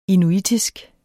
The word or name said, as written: inuitisk